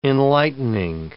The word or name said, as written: enlightening